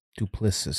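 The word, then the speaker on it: Du Plessis: Duplicis.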